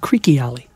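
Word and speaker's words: Creque Alley: And for the record, it's pronounced Creaky Alley, according to Denny Doherty himself.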